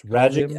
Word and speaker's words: Radjic: It's Radjic as in magic. So Radjic, that's what I thought. Radjic. – If you can say Max Magic, you can say Max Radjic.